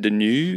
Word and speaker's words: Deneuve: Catherine Deneuve. Deneuve.